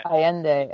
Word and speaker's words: Allende: It looks like an Isabella Allende. I hope I didn't butcher her name. – Allende.